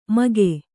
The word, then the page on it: ಮಗೆ: Kannada